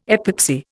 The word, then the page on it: epoxy: Indian